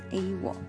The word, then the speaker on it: AWOL: 第二次世界大战之前，这成语一般念作 “AWOL”，一级，嗯，那也是可以每个字母单独发音。但战后呢，就有所改变。那不仅每个字个别，呃，字间的逗点啊都省略了，也念成 “AWOL”，成为一个新的单字。 Sorry，前面讲错了。第二次世界大战之前是念 A W O L，那在后期啊才改做 A W O 这样子的念法。